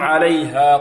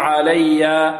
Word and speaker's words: عليها: لا نقول إذ هم عليا، عليا، لا، البعض ينطقها هكذا، إذ هم، إذ هم عليا قعود، عليا، يأتي بالهاء ضعيفة، لا، الهاء حرف ضعيف، فلا بد ماذا من تقويته،